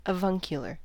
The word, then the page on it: avuncular: American English